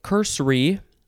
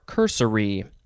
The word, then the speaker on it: cursory: Next is, you could say cursory or cursory. Two syllables or three syllables.